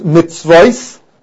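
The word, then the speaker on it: Mitzvois (מצות): One, the Chibar Ravama Yesh Ein L'Mikra, we go according to the way it's pronounced, and it's pronounced Mitzvois, Lashon Rabbim, that means you have to have at least two pieces of meat.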